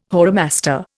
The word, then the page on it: whoremaster: Indian